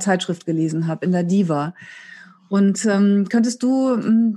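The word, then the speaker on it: DHIVA: Da ist ein stummes H drin. Also das schreibt sich nämlich D. HIV-A spricht sich Diva, aber das H ist stumm.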